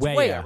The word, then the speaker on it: Weah: They scored a goal on a beautiful, beautiful, beautifully weighted pass assist from Christian Pulisic to, is it Timothy Weah? It's Weah, right, Bogus? – Yes. – Yeah, they were saying it wrong on the Fox broadcast.